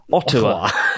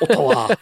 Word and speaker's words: Ottawa: Ottawa, who says, why am I pronouncing it like a Japanese way? Ottawa. Not Ottawa.